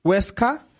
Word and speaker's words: Huesca: Oseca, I think that's how you say it. – Huesca, Villarreal, and Eibar.